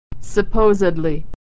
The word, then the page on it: supposedly: ed